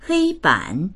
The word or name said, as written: hei1ban3